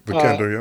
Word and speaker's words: Vikander: I think that in terms of the most substance, I think Alicia Vikander's role in Danish Girl, I hope I said her name right. – Yeah, Vikander, yep.